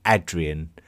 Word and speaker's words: Hadrian: So like, for example, we would believe that Trajan was probably actually called Traian. And, Hadrian would have been called Adrian. And it's just the difference in pronunciation.